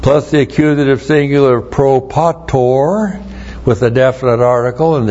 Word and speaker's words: propator: And then we have propator, P-R-O-P-A-T, long O-R, propator, which is forefather, genitive plural of the personal pronoun ego, our forefather.